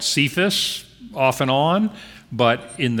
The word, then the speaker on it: Cephas: But Jesus looks at him and said, "Your name is Simon, the son of John, but you will be called Cephas." That's how we pronounce that in English, which means Peter.